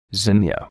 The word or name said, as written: zinnia